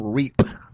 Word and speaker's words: Reep: Well, no one ever hears the name Reep. And if I say R-E-E-P, I gotta put enough space in those two E's.